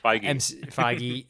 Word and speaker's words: Feige: I would have loved a proper Kevin Feige. I don't know how to say his name. – Feige MCU commissioned Venom movie.